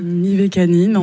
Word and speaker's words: Mivakanine: Vous pouvez nous décrire le travail de l'artiste franco-béninois Roméo Mivakana ? Mivakana ou Mivakanim ? – Mivakanine, en tout cas c'est comme ça Mivakanine. Que moi je le prononce.